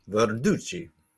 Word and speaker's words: Verducci: Verducci.